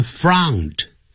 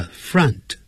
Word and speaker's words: front: in front 那个 front 发到 V 的那个发音啊，就肚子饿的饿啊，不要念成 front，叫 front he's standing in front of me，他站在我面前，我们就这样说。